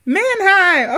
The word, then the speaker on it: Mannheim: Is that how you say it? – Right, looks like it. – Mannheim, okay.